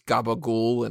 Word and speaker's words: capicola: Yeah, like, capicola wasn't capicola, it was gabagool, and it's like, the fuck does that mean, you know?